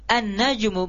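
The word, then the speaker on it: an-najmu (النجم): Kenapa sampai an-najmu bukan al-najmu? Ya, karena nun di sini ini merupakan huruf-huruf syamsiah. Ya, huruf syamsiah. Huruf-huruf syamsiah ketika masuk padanya alif lam, ya, ketika masuk padanya alif lam, maka cara membacanya dengan dileburkan.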